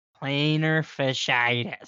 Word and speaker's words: plantar fasciitis: Plantar fasciitis.